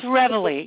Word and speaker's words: Reveille: Yeah, it's Reveille, like that little Reveille. Ditty in the okay.